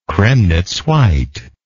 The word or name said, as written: cremnitz white